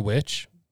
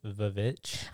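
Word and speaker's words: VVitch: The witch? – Mm-hmm. Okay. – I mean, good on him for putting the witch on there, but it's pronounced Vavitch.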